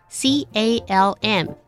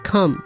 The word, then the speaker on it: calm: 好，我们要讲，如果你要把 calm 这个字当动词，我们就会说冷静，calm down，然后 calm 这个字也可以当形容词，就是平静的，那我们可以说 be calm 或者是 stay calm，那注意一下 calm 这个字的发音，c a l m 那个 l 是不发音，a 我们发 r 的音，所以是 calm，不是 come，不是 c o m e 那个 come，不是 come here， come here。